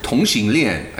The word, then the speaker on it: Tongxinglian: Tongxinglian, I think. It's been a long time.